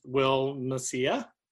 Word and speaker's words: Macia: I'm your host, and today I'm with Will Macia. Right? I said that right? – Yep, you did.